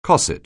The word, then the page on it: cosset: anglais